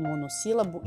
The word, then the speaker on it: monossílabo: Quando ela está acentuada como em monossílabo, eu sei que a sílaba mais forte é si, esse SI ali, né?